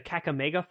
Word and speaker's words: Kakamega: the Kakamega forest. Am I pronouncing that correctly? – Yes, it's a small rainforest in Western Kenya.